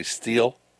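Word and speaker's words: Stihl: So the chainsaw company, I always call them Still, but it's actually Steel, but it's S-T-I-H-L. – That's pronounced Steel? – It's actually pronounced Steel.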